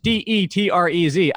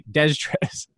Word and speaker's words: Detrez: It just would in my head, like Detrez sounds like I'm saying it wrong, but that is the correct. That Detrez is correct. It's Detrez. I keep wanting to say Destrez.